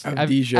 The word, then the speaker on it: Avdija: Um, Adv- I don't know how to pronounce Avdija. Av- Avdija, yeah.